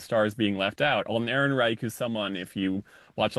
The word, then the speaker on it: Ehrenreich: There's another nod I want to give to Alden Ehrenreich. Is that how you say his name? – Ehrenreich.